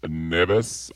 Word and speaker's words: Neves: Neves, I think, is the best way to pronounce it that I can do.